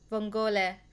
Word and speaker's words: vongole: I now know I now know how to pronounce it in Italian. Vongole.